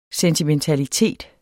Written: sentimentalitet

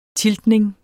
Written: tiltning

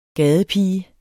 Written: gadepige

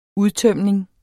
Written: udtømning

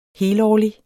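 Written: helårlig

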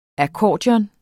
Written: akkordeon

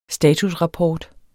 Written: statusrapport

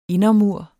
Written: indermur

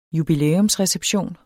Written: jubilæumsreception

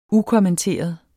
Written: ukommenteret

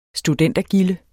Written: studentergilde

